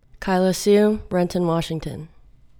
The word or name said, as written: Kaila Siu